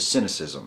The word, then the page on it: cynicism: American English